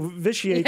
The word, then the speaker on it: vitiate: It's spelled V-I-T-I-A-T-E. Vitiate. – Well, you want to make that first T hard, don't you? Vitiate? – Mm-hmm, yeah.